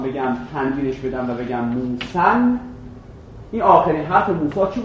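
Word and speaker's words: موسیً: الف رو که بیاندازم یه نون را باید بگم موسن. این الف رو که تلفظش نمیکنم، وقت در موسا اعراب میشه.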